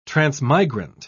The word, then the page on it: transmigrant: British English